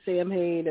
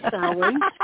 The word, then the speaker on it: Samhain: It's not a phonetic word. It looks like it should be Samhain, and boy, do I wish it were. It'd make my life easier, but it's pronounced Samhain, and it's where Halloween derives from, and it's a time of closure.